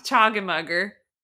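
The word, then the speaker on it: Choggenmugger: No, I said it wrong. Chogginmugger.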